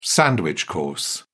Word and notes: sandwich-course: British English

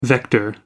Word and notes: vector: American English

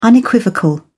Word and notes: unequivocal: British English

